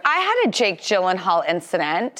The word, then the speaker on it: Jake Gyllenhaal: Rhymes with Hake Billenhall. – Yes. So I woke up Sunday morning and trending on Twitter was Jake Gyllenhaal.